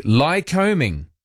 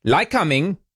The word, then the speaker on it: Lycoming: Lycoming. Although it's not like combing your hair, not that kind of combing. It's spelt Lycoming. Lycoming.